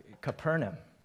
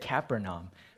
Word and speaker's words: Capernaum: Thank you, Mike, not only for the scripture reading, but also for teaching me how to pronounce Capernaum. I think last week I said Capernaum, but I think he's right, so I continue to learn from our elders.